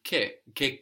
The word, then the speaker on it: che: By itself or in combination with che cosa, what thing? Which is spelled C-H-E. So C-H gives us this hard k sound.